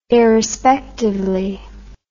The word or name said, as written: irrespectively